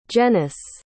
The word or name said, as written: jenis